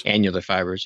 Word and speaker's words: annular: Annular fibers.